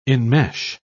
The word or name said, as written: enmesh